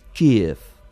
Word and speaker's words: Kiev: Aquesta pronúncia és la ucraïnesa, que sembla una mica com Kiev. – Kiev. Yeah, i l'altra, la del rus, és més o menys Kiev. Kiev. Però no és una E, tampoc.